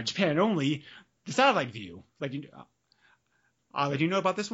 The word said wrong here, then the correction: Satellaview: I believe it's called the Satella View. – Sorry, that's how you pronounce it.